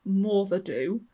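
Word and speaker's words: Moddey Dhoo: A few miles away is a waterfall haunted by Taru Ushti, a ghostly water horse or bull, and there is the Modi Du, pronounced Morthadu, a spectral black dog that haunts Peel Castle.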